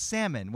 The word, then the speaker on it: salmon: He always says the letter L in the word salmon.